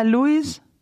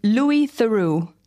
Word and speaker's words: Louis Theroux: Ja, Louis. – Louis? – Ja, guck mal, fängt schon falsch an. – Louis Theroux.